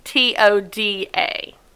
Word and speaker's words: Steatoda: So it is the class Steatodo. Stoda? Steatoda? – Just be confident. Be confident in whatever you say, and no one can say you're wrong. – I'm going to spell it. It's Steatoda.